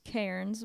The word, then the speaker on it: Cairns: Yeah, I so well, that's how they pronounce it. I'm just being a douchebag. It's Cairns, but in Australia, it's Cairns.